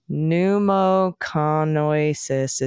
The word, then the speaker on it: pneumoconiosis: Pneumoconiosis is pronounced with all long O sounds.